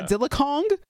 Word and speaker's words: Godzilla x Kong: You don't say the X, apparently. So it's Godzilla Kong. – That's what I was... Yeah. – Godzilla Kong?